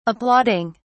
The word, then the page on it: applauding: British English